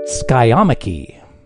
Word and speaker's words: skiamachy: And the word she provided was skyomachy. Skyomachy. And that word is spelled S-K-I-A-M-A-C-H-Y.